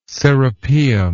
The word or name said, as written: serapeum